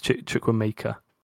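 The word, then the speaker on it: Chukwuemeka: We've got to start saying his name right, by the way. It's Chukwuemeke. – Chukwuemeke.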